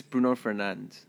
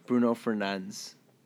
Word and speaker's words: Fernandes: It's Bruno Fernandes. – Huh? – Bruno Fernandes. Apparently that's the correct way to say it.